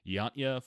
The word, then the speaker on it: Jantje: in Yanta. Uh, let me do this again. Jantje Friese. Uh, so those are the create people who created it.